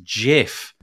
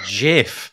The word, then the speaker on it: GIF: And his five words said, and I'm going to get them wrong, but basically it was, it's pronounced GIF. It's GIF.